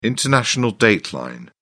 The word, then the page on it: international date line: British English